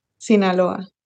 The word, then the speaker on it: Sinaloa: Sinaloa.